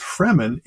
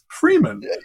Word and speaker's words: Fremen: It's not pronounced Fremen. It's Freeman.